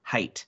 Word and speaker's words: height: If I could change one teeny tiny thing, it would be their pronunciation of the word height. There's no H on the end. It rhymes with light.